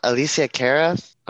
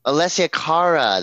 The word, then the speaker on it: Alessia Cara: Alicia Cara? – Alessia Cara? – Alessia Cara.